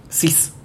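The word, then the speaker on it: Czyz: who has a four-letter surname. Cis. Is that how you say it? – I think it's like that, yes. – It's C-Z-Y-Z is the surname.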